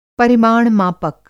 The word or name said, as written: परिमाण मापक